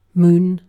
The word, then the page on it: moon: British English